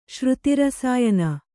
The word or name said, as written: ಶ್ರುತಿರಸಾಯನ